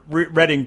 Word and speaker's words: Reading: But at least Reading, California is actually spelled Reading, where Reading, Pennsylvania is spelled Reading, but we just pronounce it.